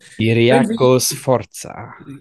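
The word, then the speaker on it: Ciriaco Sforza: Ähm, ich, und dann habe ich aber äh, Chiriaki oder Chiriakov Sforza, Aussprache von Namen. Chiriakos Sforza. – Genau, der ähm, auch damals beim Kaiserslautern bei der Meistermannschaft dabei, super wichtige Rolle gespielt, wie ich glaube Sechser oder Zehner irgendwo dazwischen.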